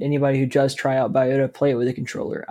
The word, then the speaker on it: Biota: and the next game on our list is Biota. – That's how you pronounce it. – Biota. Yeah. – Biota. – Or B-I-O-T-A.